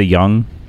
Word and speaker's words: Yung: Y-U-N-G. Am I saying it wrong? – Y-U-N-G, that's a Young? – Yeah, Elodie Young.